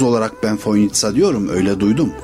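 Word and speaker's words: Fojnica: Fakat gelen misafirlerin hepsi o C'yi S olduğunu bilmiyor, TS olduğunu bilmiyor ve Foynica'ya işte gelmekten şeref duydum gibi şeyler zikrediyor. Merkeze bir yazı yazdım. Dedim ki Foynici olarak yazılan kelime telaffuzu Foynitsa şeklindedir, düzeltilmesi.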